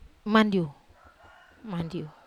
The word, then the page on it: mandiu: wamesa